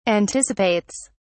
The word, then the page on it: anticipates: British English